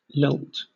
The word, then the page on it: lilt: British English